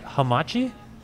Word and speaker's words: hamachi: Deep fried hamachi? – Yeah.